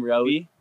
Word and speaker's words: Roey: I'm Roey. That's how you pronounce it. Like Zoe with an R.